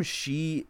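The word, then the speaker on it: sídhe: And the term she, like, I'm pronouncing it as S-H-E-E, and that's how the Irish, that's how they say it's for pronunciation.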